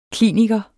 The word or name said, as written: kliniker